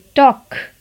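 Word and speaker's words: talk: टॉक यहाँ भी L silent होता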